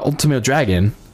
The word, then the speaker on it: Ultimo Dragon: and Ultimo Drago, uh, Ultimo Dragon. Sorry, I mispronounced that.